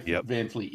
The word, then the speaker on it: Van Fleet: Am I saying that right? – I actually think they might be. – Is that the name of the band, Greta Van Fleet? – Van Fleet, yes.